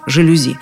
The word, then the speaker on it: жалюзи: Есть французское слово «жалюзи». Так и произносится «жалюзи».